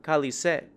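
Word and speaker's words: Cálice: As demonstrators shouted, "No amnesty and long live democracy," they sang the song Calice, Chalice in Portuguese, which is pronounced the same as Shut Up.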